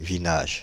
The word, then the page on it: vinages: France (Île-de-France); /vi.naʒ/